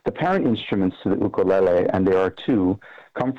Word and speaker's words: ukulele: You pronounce it differently, though, so I'd like to hear how you pronounce it. And I know it's not indigenous to Hawaii, but it's become a huge part of Native Hawaiian culture. Give us a short history of how that happened. – Sure. It's pronounced as ukulele,